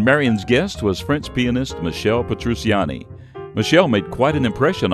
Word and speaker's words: Michel Petrucciani: Michel Petrucciani.